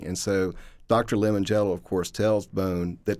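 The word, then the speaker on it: Limongello: It's Lemangello, which is pronounced Lemangello.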